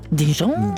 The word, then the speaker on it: Dijon: Og, og ultra uttales det Dijon. – Dijon, Dijon ja, ja, si, ja.